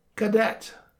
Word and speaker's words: cadet: In French, the word cadet is pronounced cadet, and the Scots changed it to caddy.